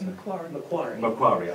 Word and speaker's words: Macquarie: She has been a distinguished visiting faculty member at Harvard University, University of California, Santa Cruz, Simon Fraser University in Vancouver, and Macquarie University in Sydney? Did I pronounce that right? – Macquarie, I'm sorry.